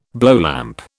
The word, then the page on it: blowlamp: British English